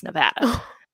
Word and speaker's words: Nevada: And then at the end, when the judges asked where we were from, they were like, "We know you're not from here because you've been saying it wrong. It's Nevada."